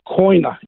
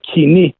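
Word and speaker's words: Koine: It's, um, it's written in the Bible twice in the original Greek language, in the we call it koina in English. They've butchered the Greek language. It's supposed to say kini, which means the common language.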